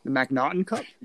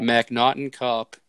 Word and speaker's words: McNaughton: The McNaughton Cup? – Brian. – McNaughton Cup.